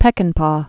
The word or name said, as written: Peckinpah